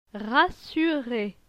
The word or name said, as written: rassuré